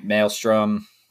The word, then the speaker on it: Maelstrom: Maelstrom, right? That's how it's pronounced. – Mm-hmm. I think so.